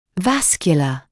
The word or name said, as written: vascular